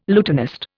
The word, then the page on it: lutenist: Indian